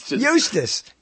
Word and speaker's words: Eustace: Eustace. – E-U. Is that how you pronounce it? Yeah, Eustace. His name is Eustace.